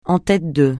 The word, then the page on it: en tête de: français